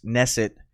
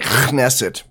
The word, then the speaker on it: Knesset: You had corrected me and said that the Israeli parliament is not Knesset, it's Knesset. – It's actually more like Knesset, but...